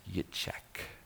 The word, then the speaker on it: Yitshak: In modern Hebrew, it's pronounced Yitshak. You may remember a prime minister of Israel being Yitshak.